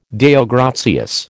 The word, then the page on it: Deo gratias: American English